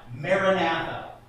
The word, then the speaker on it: Maranatha: It's a transliteration of two Aramaic words, probably pronounced something like Maranatha.